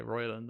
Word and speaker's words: Roiland: Something Rowland, Justin Rowland. Yeah. – No. It is. Oh yeah, it's Justin Rowland. Yeah. – Yeah. – Or is it Roiland? It's spelled Roiland. – Okay, Roiland then.